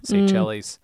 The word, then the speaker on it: Seychelles: Seychelles.